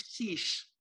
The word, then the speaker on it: Chiche: Chiche.